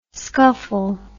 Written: skuffle

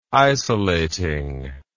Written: isolating

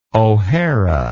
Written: ohara

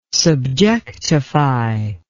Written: subjectify

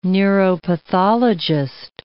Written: neuropathologist

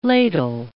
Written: ladle

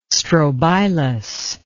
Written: strobilus

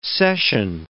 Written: session

